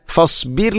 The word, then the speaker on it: فاصبر: ഇവിടെ ഫസ്ബിർ ലിഹുക്മി, അധികം ആളുകളും ഫസ്ബിർ ലിഹുക്മി എന്നായിരിക്കും പറയുക. റായിനെ കനപ്പിച്ചുകൊണ്ട് ഒരിക്കലും അങ്ങനെ പറയരുത്. ഫസ്ബിർ, റായിനെ തർക്കീക്കാണ് അഥവാ നേർപ്പിച്ചുകൊണ്ട് ഉച്ചരിക്കണം ഫസ്ബിർ.